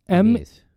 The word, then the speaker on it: Ameiz: I'm going to butcher this pronunciation, but yeah. I believe it's Amiz. A-M-E-I-Z. Excellency. Spelled X-L-N-C.